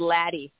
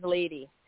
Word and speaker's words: Ladee: I get called Laddie quite a bit, but it is Lady.